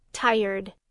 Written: tired

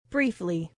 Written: briefly

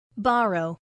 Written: borrow

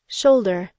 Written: shoulder

